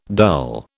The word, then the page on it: dull: British English